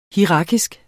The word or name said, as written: hierarkisk